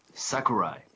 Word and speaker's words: Sakurai: I'm really excited to have David Sakurai, and I hope I said your name right, on the show. Welcome, David. – Thank you so much. My pleasure joining you. Sakurai.